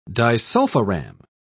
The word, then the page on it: disulfiram: British English